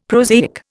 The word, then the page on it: prosaic: Indian